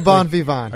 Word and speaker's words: bon vivant: Am I reading that correctly? – I'm a bon vivant.